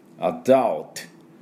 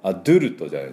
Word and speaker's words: adult: adult ね、あと DU なんですけども、アドゥルトじゃないですね。 U って書いてアと読むケースが非常に実は英語で多いんです。